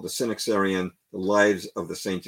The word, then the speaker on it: Synaxarion: Let's talk about early Christian history and the Synaxarion. Did I say that right? Yeah, you you said it perfectly. And um, you know the um, the uh, um, Synaxarion is a is a uh, remarkable book.